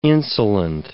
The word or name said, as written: insolent